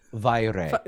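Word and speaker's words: Vairë: Spell it for me. – It's V-A-I-R-E with the little umlauts. – Vire.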